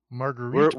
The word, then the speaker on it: Marguerite: Who's Margaret Moreau? Do we know her? – Marguerite.